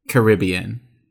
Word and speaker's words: Caribbean: This says that most natives of the islands pronounce the word with some version of emphasis on the first and third syllables. The pronunciation that's most accurate to the word's origin and most respectful to the island natives is the Caribbean.